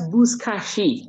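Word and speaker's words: Buzkashi: That's Bushkashi. It's sha, like the Bushkashi. So you pronounce perfect, except that sa is sha.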